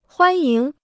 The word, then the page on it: 欢迎: huān yíng